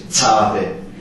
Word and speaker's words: Zade: Dieser Buchstabe heißt Zade. Uns wird wirklich das Z ausgesprochen. Das ist unser Z. Zade.